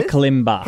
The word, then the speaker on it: kalimba: Is it kalimbo? – Called a kalimba. – Kalimba. I was going to say kalimbas, aren't they? – Kalimba.